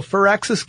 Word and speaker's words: Firaxis: Even though it's not A-X-I-S. Yeah, but it's pronounced Firaxis. Like fir tree.